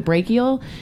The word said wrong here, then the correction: BRCA: something like BRCA. – That. BRCA gene.